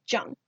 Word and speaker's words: Jung: Yeah, Shannon will say Jung. – Because I started noticing it like, later in the show when they, well, but yeah. – Got it. Okay, so you and I will pronounce his name Jung.